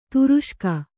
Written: ತುರುಷ್ಕ

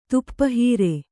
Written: ತಾಪಹರ

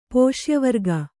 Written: ಪೋಷ್ಯವರ್ಗ

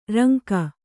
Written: ರಂಗ

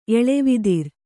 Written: ಎಳವಿದಿರ್

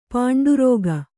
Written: ಪೌಂಡರೀಕ

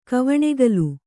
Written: ಕವಣೆಗಲು

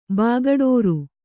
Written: ಬಾಗಡೋರು

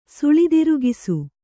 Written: ಸುಳಿದಿರುಗಿಸು

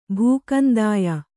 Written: ಭೂಕಂದಾಯ

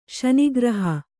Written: ಶನಿಗ್ರಹ

